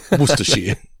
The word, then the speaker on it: Worcestershire: No, I think you pronounce it Worcestershire sauce. Worcestershire. No, you don't. It's Worcester. – Okay. – Worcestershire.